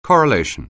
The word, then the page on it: correlation: anglais